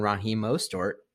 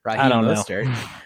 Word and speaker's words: Raheem Mostert: Raheem Mostert. Uh, among, why did I say it like that? – Raheem Mostert,